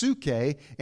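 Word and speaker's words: psuche (ψυχή): It looks like psyche, but it's actually pronounced psuche, and it means the mind.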